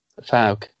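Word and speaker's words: Fouke: Folk or folk monster? – Falk. – Falk monster.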